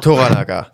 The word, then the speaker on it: Toranaga: Wir begleiten aber diesen Lord Toranaga, genau. So ist es ausgesprochen, richtig.